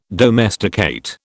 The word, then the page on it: domesticate: British English